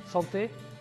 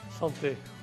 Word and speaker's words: Santé: how do you say Santé? Slants, slants, sorry, slants, slants. santé, santé.